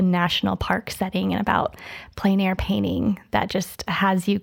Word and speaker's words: plein air: One of the mediums you thoroughly enjoy is plein air painting. – Yes. – Did I say that right? – Yes, plein air painting.